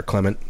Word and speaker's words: Clement: Obviously, namely Jemaine Clement, or Clement, how it's pronounced.